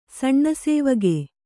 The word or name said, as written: ಸಣ್ಣಸೇವಗೆ